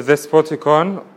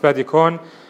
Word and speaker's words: Despotikon: The middle part is called the Despotikon, or sometimes pronounced Spadikon.